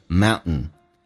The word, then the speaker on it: mountains: 那这边有个单词 mountains，有时在口语化当中 T 跟 N 组合， T 可以不用发音变成 mountains.